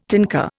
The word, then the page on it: tinker: Indian